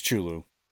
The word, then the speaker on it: Cthulhu: What Cthulhu? It's great. Cthulhu. No, nobody knows how to say it. Nobody knows how to say it. Chulu. It's Chulu.